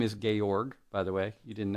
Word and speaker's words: Georg: Georg. It's German sort of pronouncing George. G-E-O-R-G.